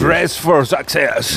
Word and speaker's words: Dressed for Success: Dressed for Success, Roxette. La verdad es que es difícil de pronunciar esto, ¿eh? Hazlo tú, que eres más inglés. – Dress for Success. – ¿Ves? A que le sale mucho mejor.